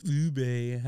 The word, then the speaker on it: ube: Ube.